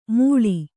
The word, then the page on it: ಮುಳಿ: Kannada